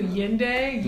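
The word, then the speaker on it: Nyende: Yende. Currency. The N is silent.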